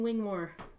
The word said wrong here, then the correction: Wigmore: Wigmore. Wing. – Wing. There's no N. – Wig. – Wigmore, yeah.